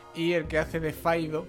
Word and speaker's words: Fido: Decía, una película canadiense, independiente, del año 2006, llamada Fido, o Faido, en realidad, se pronuncia Faido, que es de Andrew Curry, sí, como la especia, pero sin la Y.